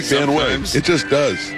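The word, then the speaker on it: Van Wey: Van Wy. Logan, yep. Van Wy. 2-2 pitch is fouled back. It is spelled Van Wy, but it is Van Wy.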